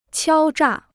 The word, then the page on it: 敲诈: qiāo zhà